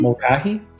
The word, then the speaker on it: Mulcahy: Mulcahy. – Mulcahy.